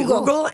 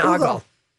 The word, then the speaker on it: ogle: I've heard people say oogle and ogle. – It's an oogle google. Mm-hmm. Lori, okay, what do you do? – Dictionary gives it a long O. – A long O. – Ogle.